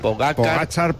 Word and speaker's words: Pogačar: Sí, Tadej Pogačar, Pogačar.